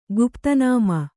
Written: ಗುಪ್ತನಾಮ

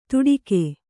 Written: ತುಡಿಗೆ

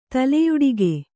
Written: ತಲೆಯುಡಿಗೆ

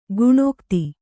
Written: ಕಣ್ಗೆತ್ತು

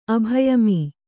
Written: ಅಭಯಮೀ

